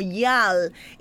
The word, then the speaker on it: gyal: Es más o menos la raíz del cual se desprende esta palabra, pero luego cambia la manera de escribirse a gyal, y luego se pronuncia como yal, y luego lo llevamos a la pluralidad de yales para referirnos a las mujeres.